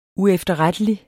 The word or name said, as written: uefterrettelig